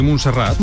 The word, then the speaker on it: Montserrat: En la agenda de hoy tiene previsto acudir a la cárcel de Brians, visitar Montserrat y celebrar una misa en la Basílica de la Sagrada Familia. ¿Lo he dicho bien? ¿Montserrat? – Sí, Montserrat.